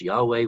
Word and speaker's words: Yahweh: That's a tetragrammaton, which we pronounce Yahweh, which later became Jehovah.